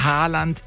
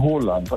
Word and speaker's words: Haaland: Er wird ausgesprochen Haaland, also mit O. – Ja. – Haaland und zwei A's werden als O ausgesprochen und ein A mit dem Kringel oben drauf ist eigentlich auch eine Aussprache, ein O.